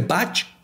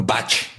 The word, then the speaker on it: Bach: interpretando noches enteras clásicos de Bach, Liszt, Mozart y Brahms. Y estoy seguro que él sí lo pronunciaba bien. Él decía Bach, no Bach. Dije Bach, ¿verdad? Bach, Bach, Bach.